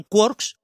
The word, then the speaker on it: quark: Y, pero se popularizó el término de quarks, hay que pronunciar quark porque es lo que le gustaba a eh, tiene que rimar con Cork, con una ciudad de Irlanda.